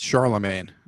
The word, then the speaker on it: Charlemagne: It's Charlemagne. You don't pronounce every syllable in there. – No, well, there's a G. – It's silent.